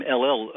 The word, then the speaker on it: Ellel: And just for our listeners, if you don't mind me saying, the name LL, for those who are wondering, it's spelled E-L-L-E-L.